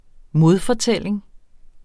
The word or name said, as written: modfortælling